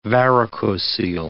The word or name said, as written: varicocele